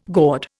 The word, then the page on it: gourd: Indian